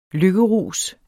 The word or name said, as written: lykkerus